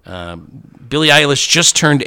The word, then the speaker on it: Eilish: It's that pop star Billie Eilish. – It's pronounced Eilish, huh? – Billie Eilish, yeah.